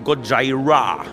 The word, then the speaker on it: Gojira: Also es kam dieses Jahr ein neues Gojira, Gojira Album raus. Ich weiß ja nicht, weißt du, wie man es ausspricht? Das sagst du jedes Mal bei diesem Namen. Gojira. Man sagt einfach Gojira.